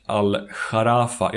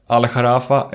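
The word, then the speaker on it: Al-Gharafa: Han kom att spela för tre qatariska klubbar i form av Al-Sad, Al-Rayan och Al-Sharafa. Jag tror man uttalar det sistnämnda så. Det stavas G-H-A-R-A-F-A. Hans kontrakt med Al-Sharafa,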